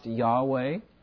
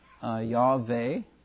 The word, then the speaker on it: Yahweh: If the vowels in the word Adonai are placed in the consonants Y-H-W-H, you get Jehovah. In the days of the Bible, it was pronounced Yahweh. Sometimes Yahweh, depending on which nationality and which pronunciation, the W is pronounced as a V in Germany.